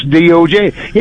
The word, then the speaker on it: Doge: And if you go look up the pronunciation, it's DOJ. Yeah, it is.